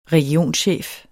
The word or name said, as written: Regionchef